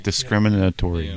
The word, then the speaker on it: discriminatory: Discriminatory. How do you say it? – Discriminatory. – Discriminatory. Yeah. I missed the letter.